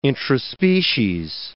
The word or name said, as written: intraspecies